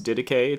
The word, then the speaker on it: Didache: What looks like maybe Didache, the wacky Didache, it's pronounced Didache. It's a Greek word that means teaching or the teaching.